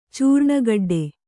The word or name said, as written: ಚೂರ್ಣಗೆಡ್ಡೆ